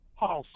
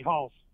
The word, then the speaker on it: Halz: Halls. H-A-L-Z. Halls. Yes, sir. – Almost like Haas.